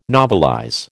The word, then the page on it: novelize: American English